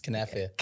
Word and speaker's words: Kenefa: Kenefa. Kenefa. – Well, yeah, well done. – Kenefa. – Yeah, so the K is not silent. It's Kenefa.